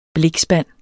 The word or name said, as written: blikspand